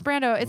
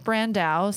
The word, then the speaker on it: Brandau: My last name's Brando. It looks like Brandow. Sounds like I'm pronouncing it wrong, but it's pronounced Brando, like Mark.